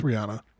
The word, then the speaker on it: Rihanna: or is it Rihanna? Is that how you say her name? – It's Rihanna. – It's Rihanna, okay.